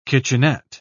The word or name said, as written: kitchenette